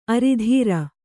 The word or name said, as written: ಆರಿದ್ರೆ